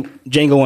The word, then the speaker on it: Django: Yeah, it's a real quick scene where like Django's like Jamie Foxx, Django tells him, he's like, you know, what's your name? He's like, Django. How do you spell it? D-J-A-N-G-O. He's like, the D's silent.